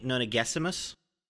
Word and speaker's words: Nonagesimus: basically an indentured servant to one of the other main characters, Harrowhark Nonagesimus. I hope I pronounced that right. There's a pronunciation guide, and I'm still bad at it. – I think it's a hard G, Nonagesimus.